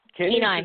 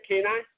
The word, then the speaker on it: Kenai: The proposed ordinance also cites complaints about the impact of a growing cat population on the Kenya animal shelter resources. – Kenai. – Is that how you pronounce the city name, Kenai? – Yeah, Kenai. – Okay, Kenai.